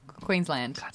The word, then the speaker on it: Queensland: Brisbane is the capital and most populous city in the Australian state of Queensland. Now, also... – Queensland. Sorry, I know. Your emphasis is just on the wrong syllable, but you did pretty good.